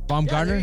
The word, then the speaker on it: Baumgartner: Baumgartner.